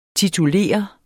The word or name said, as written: titulere